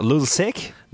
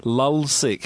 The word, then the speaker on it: Lulzsec: Lulzsec? – Not quite. Lulzsec.